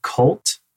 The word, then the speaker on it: CVLTE: Next, there's a single from a band called Cult, but it's spelled C-V-L-T-E.